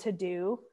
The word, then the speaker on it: TeuxDeux: And I actually use a really awesome app called To-Do. It's spelled T-E-U-X-D-E-U-X. It is paid, but it's like $2 a month, so it's really cheap.